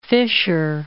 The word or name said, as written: Fischer